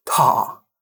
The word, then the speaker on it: Ptah: Now, it's spelled weird if you're going to look it up. It's P-T-A-H, Ptah.